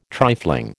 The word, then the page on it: trifling: American English